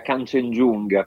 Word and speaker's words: Kanchenjunga: Allora, in realtà la pronuncia corretta io mi immagino sia Kanchenjunga, però è una traslitterazione, in realtà è il nome di una delle vette dell'Himalaya.